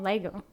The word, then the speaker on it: Lego: I was obsessed with Legos. Do you even pronounce it Legos? – We say like Lego.